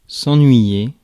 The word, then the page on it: bug: /bʌɡ/